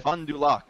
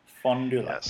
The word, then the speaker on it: Fond du Lac: Well if you watch SNL, it's Fond du Lac, Wisconsin. – Fond du Lac? – Fond du Lac. – Yes, yep. – Very good. – How do you say that? – Fond du Lac. – Fond du Lac? – Fond du Lac. Huh.